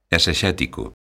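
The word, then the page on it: esexético: Galician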